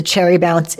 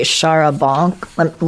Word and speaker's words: charabanc: No, actually, in this chapter, it's the Cherry Bounce is Hannah the Irish Maid's mispronunciation of the charabanc.